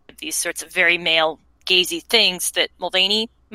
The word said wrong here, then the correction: Mulvey: Molly, am I saying that right? I'm terrible with names. – Mulvey. – Okay.